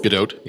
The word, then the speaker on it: Gadot: How many, Gadot? – Gadot, yeah. – That is the official pronunciation we've been getting.